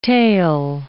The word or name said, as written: tale